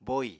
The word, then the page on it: bóì: apatani